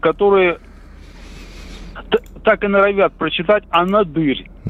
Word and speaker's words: Анадырь: Но правильно, анадырь, естественно. Вы сейчас...